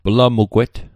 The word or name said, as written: plamugwet